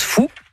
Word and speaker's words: Faou: Allez, moi, je m'occupe de Frédéric, qui est donc habitant du château du Faou, mais qui se prononce Fou.